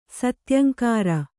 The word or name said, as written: ಸತ್ಯಂಕಾರ